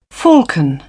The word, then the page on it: falcon: British English